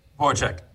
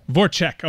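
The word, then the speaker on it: Voráček: Now, I will lean on you for the pronunciation because this guy's apparently a pretty good player. Jacob Voracek? – Voracek. – Voracek, all right, from the Philadelphia Flyers.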